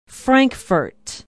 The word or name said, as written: Frankfort